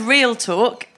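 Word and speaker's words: 'Rial Talk: It's called Real Talk. It's spelt apostrophe R-I-A-L as in mater. Real talk. It's a visual pun for an audio medium.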